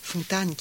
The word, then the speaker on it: Fontane: On connaît bien l'exemple du père de l'écrivain Fontane, et je ne prononcerai pas Fontane, car lui-même ne voulait pas qu'on le prononce à l'allemande. Fontane,